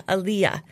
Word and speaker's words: Aaliyah: And it's one of the alleged victims was Aaliyah, right? Do I say that right? – Aaliyah.